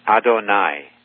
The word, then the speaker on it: Adonai: It rhymes with El Shaddai. Adonai. That's the way that's pronounced.